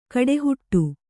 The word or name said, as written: ಕಡೆಹುಟ್ಟು